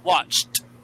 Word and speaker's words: watched: Watched. – Watched. – Make sure that, yeah, make sure that T sound on the end there.